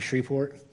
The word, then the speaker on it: Shreveport: I spent the first year I was here learning how to pronounce Shreveport. There's no Shreveport. Every place else I've been, it was Shreveport is how I pronounced it until I moved here. And then I was corrected very strongly. You don't say that. You say Shreveport.